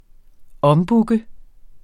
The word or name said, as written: ombooke